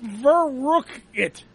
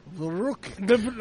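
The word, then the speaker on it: Verrückt: It's pronounced Verrucket. – Verrucket. – Like for the rocket, I guess.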